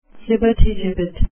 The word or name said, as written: flibbertigibbet